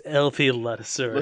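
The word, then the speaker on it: LP Ladouceur: Dan Bailey, Chris Jones, and LP Leducer. – Lattisseur, no, for God's sakes. LP Lattisseur.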